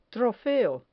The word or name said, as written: trofeo